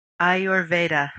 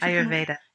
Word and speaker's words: Ayurveda: A simple way of doing it is to just say Ayurveda. – Ayurveda. – Yeah. – And Ayurveda. – If you can roll your R's, I can't do it very well, but if you can, that helps. – Ayurveda.